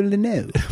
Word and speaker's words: Lynott: Phil Lynott. – That's how they told me in Dublin it's pronounced right.